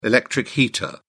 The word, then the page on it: electric heater: British English